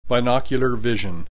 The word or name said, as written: binocular vision